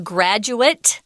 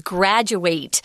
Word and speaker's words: graduate: I wanted to mention there's a difference in pronunciation. The words are spelled the same. The noun is a graduate. Mm. Graduate, eh sound at the end. And the verb is graduate. So I could say I'm a college graduate or he's a high school graduate. But you graduate, ate, graduate is the verb.